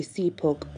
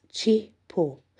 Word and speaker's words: Cepuk: Because Rangda is potentially dangerous, his name is spelled C-E-P-U-K, which you would imagine would be Sepuk, but actually it's not. It's pronounced Chipo, C-H-E-H-P-O-O.